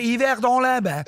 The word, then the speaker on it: Yverdon-les-Bains: Ma sei bellissimo, sei bravissimo a pronunciarlo. – È l'unica cosa che so fare in francese, dire Yverdon-les-Bains, è quello, è così.